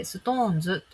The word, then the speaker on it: SixTONES: シックストーンズと書いて、ストーンズと読むんですけど、